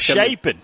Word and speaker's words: Chapin: Chapin is that not it? Chapin. – Right, we have to learn pronunciations when new guys. – Shapen. Shapen. Shapen.